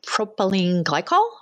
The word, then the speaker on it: propylene glycol: And I didn't know about, and I can't ever pronounce it for some reason, propylene glycol. Oh, I said it right. – Yeah, you did.